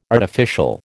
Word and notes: artificial: American English

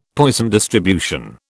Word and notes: Poisson distribution: British English

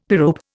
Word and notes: pyrope: Indian

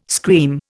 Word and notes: scream: Indian

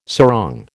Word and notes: sarong: American English

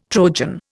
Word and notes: Trojan: Indian

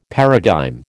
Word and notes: paradigm: American English

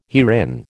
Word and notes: herein: American English

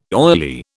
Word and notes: oily: British English

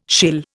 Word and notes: shill: Indian

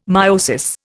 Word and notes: myosis: Indian

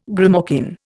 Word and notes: grimalkin: Indian

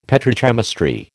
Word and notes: petrochemistry: American English

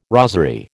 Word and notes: rosery: American English

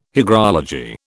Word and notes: hygrology: British English

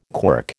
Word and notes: quirk: American English

